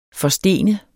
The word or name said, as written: forstene